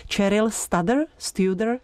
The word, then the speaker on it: Cheryl Studer: Vždycky u této americké sopranistky nevím přesnou výslovnost, tak doufám, že mě pomůžete. Cheryl Studer? – Já bych řekla, že to říkáš dobře, já bych to určitě neřekla tak hezky anglicky.